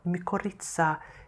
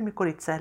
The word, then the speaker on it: mykorrhiza: Det här ska vi också prata om, mykorrhiza svamparna. Och då vill jag fråga dig, heter det mykorrhiza eller mykorrhiza? Vad säger du? – Det låter som grekiska. Då kommer betoningen i mitten, eller hur?